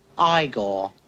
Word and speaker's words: Igor: You must be Igor. – No, it's pronounced Igor.